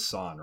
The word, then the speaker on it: San: But I do remember, I don't want to pronounce her name wrong, it is San, right? – Mm-hmm.